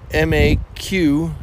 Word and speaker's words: Maquis: Maquis, like marquee, but without the R. Yeah. – Maquis.